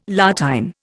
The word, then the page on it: La Tène: Indian